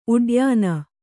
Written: ಉಡ್ಯಾನ

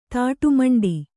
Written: ತಾಟುಮಂಡಿ

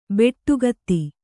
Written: ಬಟ್ಟಗೈದು

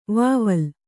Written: ವಾವಿಲಿ

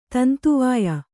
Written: ತಂತುವಾಯ

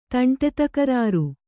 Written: ತಂಟೆತಕರಾರು

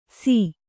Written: ಸೀ

